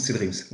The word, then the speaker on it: C3Dreams: Cédric, oui, sous le pseudo C3 Dreams, ça s'écrit comme ça, mais ça se prononce C Dreams, Et pourquoi c'est Dreams d'ailleurs?